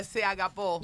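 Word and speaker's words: Se agapó (σ' αγαπώ): Pero mira, y los griegos dicen se agabó. Ah. Se agapó. Oh, se agapó.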